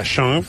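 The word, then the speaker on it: Książ: Pues mira, en los alrededores, la provincia de Baja Silesia, además he visitado hoy, está el castillo de Sonz, que para que veas cómo es el polaco, se escribe K-S-I-A y se pronuncia Sean, o sea, no tiene nada que ver.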